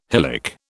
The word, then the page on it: hylic: British English